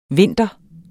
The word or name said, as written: vinter